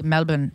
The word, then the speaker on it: Melbourne: Melbourne. Erin, she spells it E-R-I-N-N-A.